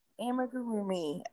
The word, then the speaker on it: Amigurumi: Tell us how it's correctly pronounced, what you do. – Amigurumi. – Okay. – Amigurumi, yes.